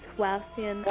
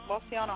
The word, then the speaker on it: Wauseon: Butcher this, but it's Wauseon, Ohio. – Yep, Wauseon, Ohio.